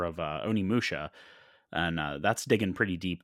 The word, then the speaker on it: Onimusha: Onimusha. – Onimusha, yeah.